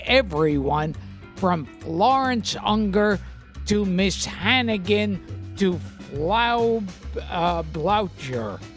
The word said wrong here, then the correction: Blücher: Frau Blücher.